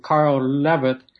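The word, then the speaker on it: Löwith: to Carl Levitt, I believe is how it's pronounced, saying that I'm a fascist, basically.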